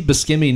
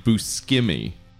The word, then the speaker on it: Buscemi: It also makes me wonder, like, why does Steve Buscemi not always have long hair and a goatee?